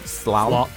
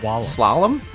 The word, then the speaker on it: slalom: We'll go with salam. I've never heard of it used as a verb before. Down a mountain randomly generated with unpredictable obstacles. Claim a cash. – Slalom. – Slalom? Not a silent L.